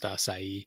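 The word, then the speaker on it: açaí: Uh, at the at the risk of sounding pedantic, uh, uh just to save you from future embarrassment, uh, that fruit is pronounced acai. Uh, acai. Yeah. just want to make sure we get that right. For I believe that's like a Portuguese word.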